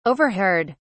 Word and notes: overheard: British English

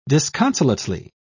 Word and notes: disconsolately: British English

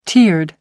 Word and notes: tiered: American English

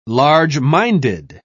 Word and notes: large-minded: British English

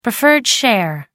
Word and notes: preferred share: American English

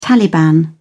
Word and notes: taliban: British English

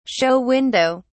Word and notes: show window: British English

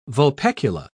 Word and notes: vulpecular: British English

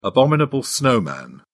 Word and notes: abominable snowman: British English

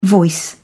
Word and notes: voice: British English

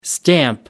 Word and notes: stamp: American English

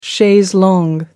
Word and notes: chaise longue: American English

